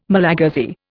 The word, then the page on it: Malagasy: Indian